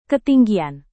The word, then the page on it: ketinggian: indonesian